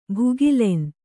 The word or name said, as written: ಭುಗಿಲೆನ್